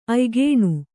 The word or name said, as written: ಆಯ್ಕನೆ